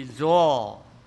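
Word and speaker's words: zo (ζῶ): 这个活着不是一般的活着，那个希腊文原文的发音呢，近似于 zo。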